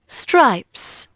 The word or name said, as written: STRIPES